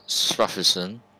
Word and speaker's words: Smuffisun: Mr. Smuffinson is the last name? That's how to pronounce it? – Smuffisun. – Smuffisun, yeah, okay.